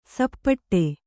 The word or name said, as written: ಸಪಾಟಿ